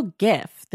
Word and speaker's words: gif: Oh, you go jiff with a hard J like peanut butter. I go gif.